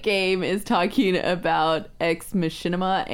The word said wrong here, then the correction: Machina: and BB-8. Because he can't stop. – Machina. – Machina. Oh, sorry.